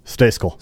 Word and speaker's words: Stayskal: Stayskal.